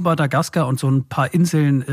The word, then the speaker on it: Madagaskar: Betonung auf der letzten Silbe. Madagaskar.